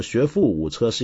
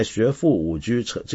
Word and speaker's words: 学富五车: 在成语中，车指的是书，而非具体的交通工具或机械，因此正确的读音呢，应该是车。虽然古音中车有时候读作车，尤其是在象棋术语，但在学富五车这个成语里面应该读作车。